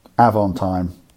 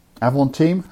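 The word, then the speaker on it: Avantime: Yeah, Avant time. Avant team? How are you going to pronounce that? – Avant team, I think.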